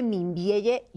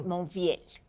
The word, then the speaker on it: Membielle: Se escribe Membielle, yo le digo Membiel, hay quien dice Monfiel.